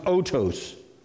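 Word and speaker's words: otos (οὕτως): In fact, in the original Greek, the Greek word for it, I wrote it down there, and if you don't know Greek, it's pronounced otos.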